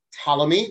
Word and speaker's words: Ptolemy: So in Egypt, it was ruled by a fellow named Ptolemy, with a silent P at the beginning, P-T-O-L-E-M-Y.